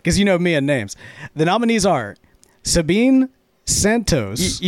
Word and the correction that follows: Sabina Santos: You already butchered it. – dang it. And John David will read off the nominees. – Yeah, it's Sabina Santos, and we have Tom Eltang, Nate King, Micah Kreider with Yeti Pipes, and Chris Astero.